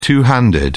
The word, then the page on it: two handed: British English